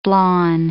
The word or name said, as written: blawn